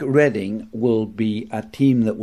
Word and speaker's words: Reading: Now, Reading, which is an interesting English word because it's the same spelling as Reading, but it's pronounced Reading.